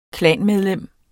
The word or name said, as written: klanmedlem